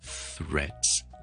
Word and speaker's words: threads: 前面轻轻卷嚟啊，reds，跟住佢读完个 reds 之后咧，我前面轻轻做翻个 th 亦都系啦，脷尖放喺上面嘅门牙下方，轻轻喷气。跟住完之后直接扔落去个 reds 度，听住 threads， and that's how you pronounce threads。